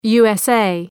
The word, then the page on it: USA: ,ju:əs’eı